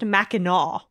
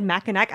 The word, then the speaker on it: Mackinac: It gets confusing because Fort Mackinac is pronounced with the knack, but for whatever reason, the island is naw.